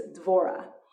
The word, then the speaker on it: Dvorah: And then the final thing, I started off by telling you that in the Hebrew, her name is Dvorah. In English, we put in the syllable, consonant, what is it called? The letter. We put in the letter E, and we make it Deborah.